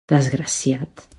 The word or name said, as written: desgraciat